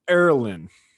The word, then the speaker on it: Ierlan: Welcome to the show, TD Ierlan. – Thanks for having me. That was good pronunciation. That was good. No one ever gets that right.